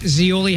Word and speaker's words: Zeoli: It's me, Rich Zeoli, hanging with you today. I have my podcast. You can check it out wherever you get your podcasts. Z-E-O-L-I. That's how you spell it. Just like the Olive Garden.